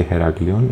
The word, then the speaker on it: Heraklio: If the signs are old, they say Heraklion.